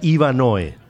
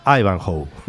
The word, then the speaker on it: Ivanhoe: de una de las novelas más emblemáticas de ese género, se llama, yo decía Ivanhoe, pero se pronuncia Ivanhoe en inglés.